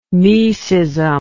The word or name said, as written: mecism